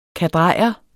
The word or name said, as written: kadrejer